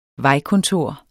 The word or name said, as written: vejkontor